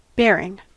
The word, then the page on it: bearing: American English